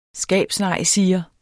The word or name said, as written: skabsnejsiger